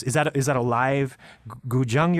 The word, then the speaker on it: guzheng: And Ian, the next track I wanted to play, this was one of my favorite tracks on the EP, and I believe the pronunciation is guzheng, which is a traditional Chinese zither instrument, and it's traditionally used, you know, to make this very melodic kind of music.